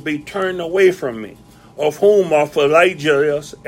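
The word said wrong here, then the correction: Phygelus: and Hermogenes. Philegius and Hermogenes. I had said that, I pronounced that two or three times, but now it's like I'm wondering what it was that she told me about my thermos. Phygellus and Hermogenes, the Lord give mercy unto the house of Onesiphorus, for he oft refreshed me and was not ashamed of my chain.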